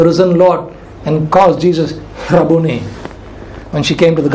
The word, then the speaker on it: Rabboni: The only other place where this word Rabuni is used, that's how it's pronounced. Rabuni, actually. Rabuni. There's a H there.